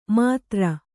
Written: ಮಾತ್ರ

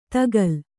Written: ತಗಲ್

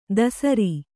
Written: ಧಸರಿ

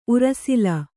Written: ಉರಃಸೆಲ್ಲ

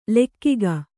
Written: ಲೆಕ್ಕಿಗ

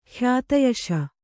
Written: ಖ್ಯಾತಯಶ

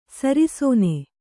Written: ಶರಾಸನ

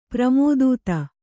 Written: ಪ್ರಮೋದೂತ

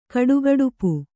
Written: ಕಡುಗಡುಪು

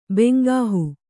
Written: ಬೆಂಗಾಹು

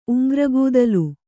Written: ಉಂಗ್ರಗೂದಲು

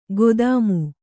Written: ಖದೀಮ

